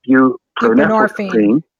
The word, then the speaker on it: buprenorphine: Buprenorphine, thank you.